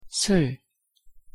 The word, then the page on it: 술: Korean